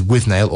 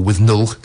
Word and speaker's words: Withnail: Grant, who is Withnail or Withnull, depending on how you want to pronounce it.